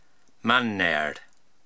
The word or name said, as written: Manneyr